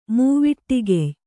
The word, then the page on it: ಮೂವಿಟ್ಟಿಗೆಯ್: mūviṭṭigey